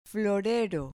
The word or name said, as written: florero